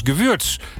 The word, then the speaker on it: Gewürz: Nou, Paul zegt, Bert, het is Gewürz. Spreek je uit met een U-klank.